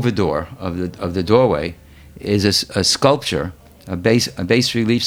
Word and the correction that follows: bas-relief: sculpture. Is that how you say it? – Bas-relief.